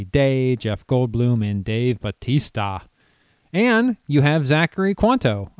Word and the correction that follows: Quinto: or Quanto. Oh boy, I should have looked up how you say Quinto. – It. Quinto? Is that how you say it? – Yes.